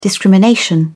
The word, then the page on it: discrimination: British English